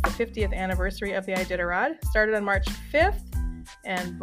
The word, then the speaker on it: Iditarod: Iditarod is how you say that. It's a weird word.